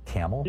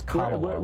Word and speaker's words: Kamala: Like camel? – Yeah. – Really? It's Kamala.